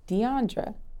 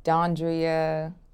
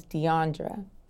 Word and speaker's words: Deiondra: The correct way to pronounce my name is Deiondra. – Do people mispronounce this? – Yes, I'm called Deiondra, Dondria, Deiondre. It is Deiondra.